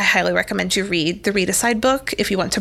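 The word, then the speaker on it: Readicide: For all of you out there listening in podcast land, Read Aside by Kelly Gallagher. It is life-changing. You spell it like homicide. It's R-E-A-D-I-C-I-D-E. Read Aside.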